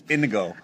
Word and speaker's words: Inigo: Inigo.